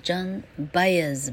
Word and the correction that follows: Joan Baez: Joan Baez，Joan J O A N，Baez，B A E Z，B A E Z。